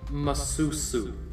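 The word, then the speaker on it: Mušḫuššu: Masusu. – Masusu? – You added a couple extra syllables, love. I am sorry to inform you. Masusu. Say it with me now.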